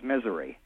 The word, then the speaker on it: Missouri: Yeah, well, it's actually, if you've ever been here, it's misery. – Misery.